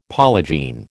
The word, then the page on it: polygene: American English